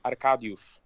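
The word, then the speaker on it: Arkadiusz: Hur, hur uttalar du Miliks förnamn? – Arkadius.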